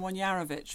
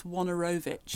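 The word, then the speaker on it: Wojnarowicz: "The first thing is you must pronounce his name correctly. Apparently it's Wonorowicz."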